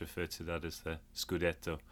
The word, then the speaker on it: Scudetto: I was about to say you'd have another Scudetto. Is that correct? – That's correct. Yeah, the Italian championship is called the Scudetto.